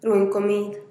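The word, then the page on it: ruin komiid: Teotitlán del Valle Zapotec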